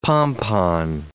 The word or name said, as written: pompon